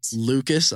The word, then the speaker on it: Lucas: Lucas, L-U-C-A-S dot F-R-A-N-K-L-Y-N, spelled the same way as Franklin by Sophia.